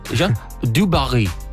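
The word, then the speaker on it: Jeanne du Barry: Jean Dubarry.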